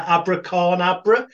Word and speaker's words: AbraCORNAbra: I can never pronounce it right. Abracorn Dabra. That's it.